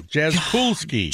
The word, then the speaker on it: Jaskulski: Well, it's K-U-L. Jaskulski.